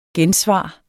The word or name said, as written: gensvar